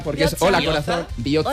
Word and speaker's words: Biotza: Con TZ. Ojo, TZ. – Biotza. – Que no tenemos TH, tenemos TS, TZ y TX. La TX se pronuncia como ch. La TZ como. Y la TS como TS, o sea, como una CH normal.